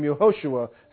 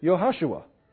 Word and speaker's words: Yehoshua: Now, Joshua, in the day that Joshua lived, you would have pronounced it Yehoshua. But in the day that Jesus lived, by that time that name had changed enough that it was called Yeshua.